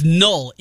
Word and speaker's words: Noel: Joel, what's Noel? Noel. – Noel. Jaylin Noel. Get his name right. Noel, not Noel, Noel. It's null in the pronunciation guide.